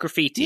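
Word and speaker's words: Grafite: I'll go with Graffiti. I think that's how you say his name. Like Graffiti. – Yeah. – Graffiti, Brazilian striker who made his name with Wolfsburg in 2008-09.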